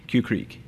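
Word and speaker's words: Que Creek: It's in case you don't know what I'm saying, it's spelled Q-U-E Creek, Cue Creek.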